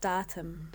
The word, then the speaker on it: DAHTM: Now, this next band you've selected for us, is it, how do I pronounce it, D-A-H-T-M? – I believe it's pronounced Datum.